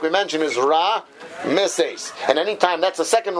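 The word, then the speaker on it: Ra-Meses (רעמסס): The reason why it's pronounced Ra-Meses is because the ayin has a shva, so it's Ra. Now, the reason this word often is pronounced wrong is because Ashkenazim don't pronounce an ayin. We do ayin like an aleph, which has no sound, so therefore it sounds like Ram-Meses.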